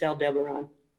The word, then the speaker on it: Aldebaran: I always pronounce that wrong. – Yes. Well, I have to concentrate to say it because my mind wants to do something else with those letters. It's Aldebaran.